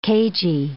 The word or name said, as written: cagy